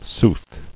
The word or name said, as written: Soothly